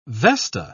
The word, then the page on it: vesta: British English